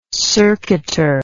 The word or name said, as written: circuiter